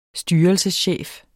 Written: styrelseschef

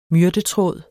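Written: myrtetråd

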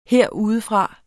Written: herudefra

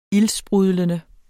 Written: ildsprudlende

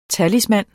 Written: talisman

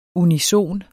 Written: unison

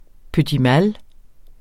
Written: petit

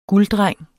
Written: GULDDRENGENS

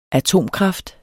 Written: atomkraft

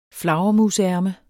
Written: flagermusærme

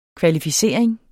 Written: kvalificering

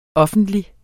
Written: offentlig

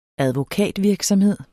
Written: advokatvirksomhed

